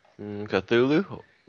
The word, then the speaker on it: Cthulhu: And does this relate into anything that what Earth was calling them, Cthulhu, or whatever the heck that is? – Cthulhu? It rhymes with Kazuzu, which was a Futurama monster.